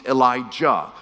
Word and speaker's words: Elijah: So before Elisha, his teacher was a prophet named Elijah with a hard J.